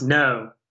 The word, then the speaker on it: Ngo: It's pronounced no.